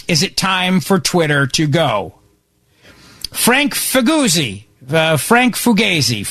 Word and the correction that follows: Figliuzzi: from MSNBC. I think it's Figliuzzi is how you say it.